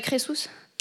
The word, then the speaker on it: Crésus: Oui, oui, c'est pas Crésus, hein, c'est Crésus. Oui. Oui, comment ça s'écrit ? – C-R-E-S-U-S.